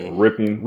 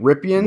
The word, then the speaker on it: Rypien: Yeah, Rypien. – Rypien.